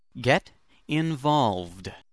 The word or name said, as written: get involved